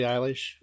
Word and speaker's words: Eilish: You know, you've covered Billie Eilish.